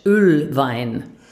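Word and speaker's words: Oelwein: Ausgesprochen Deutsch, Ölwein.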